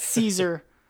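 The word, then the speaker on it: Szczur: Because his name is Matt Caesar, and it certainly is not spelled that way. His last name is spelled S-C-Z-C-U-R.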